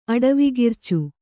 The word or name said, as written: ಅಡವಿಗಿರ್ಚು